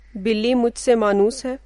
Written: मानुस

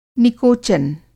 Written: निकोचन

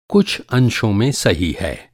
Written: अंश

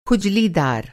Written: खुजलीदार

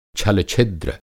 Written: छल-छिद्र

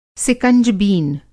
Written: सिकंजबीन

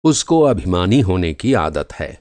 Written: अभिमानी

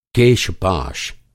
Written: केश-पाश

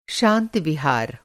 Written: विहार

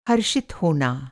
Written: हर्षित

